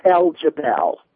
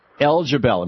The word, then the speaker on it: El Jebel: It's El Jebel. – El Jebel, thank you. El Jebel, okay. – It's Arabic, and it means the rock.